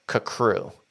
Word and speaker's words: CCRU: And at one point they say that it's pronounced Kakru.